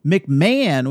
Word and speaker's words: McMahon: This week on The Ready Room, we had Lower Deck's showrunner Mike McMahon, which I just learned you pronounce his name McMahon, and Dawn Lewis, one of the actors who plays the captain.